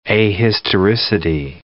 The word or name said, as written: ahistoricity